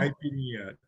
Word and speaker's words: caipirinha: Caipirinha.